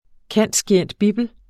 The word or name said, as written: cand.scient.bibl.